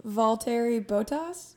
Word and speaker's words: Valtteri Bottas: So I guess starting off with round number five, I'm going to go with Valtteri Bottas. – That was close. Valtteri Bottas, that was close, but that was a 9 out of 10 pronunciation actually for the first time reading it, and that's a good pick.